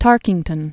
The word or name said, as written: Newton Booth Tarkington